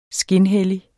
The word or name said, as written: skinhellig